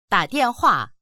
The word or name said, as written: da3dian4hua4